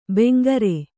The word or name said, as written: ಬಂಗಾರ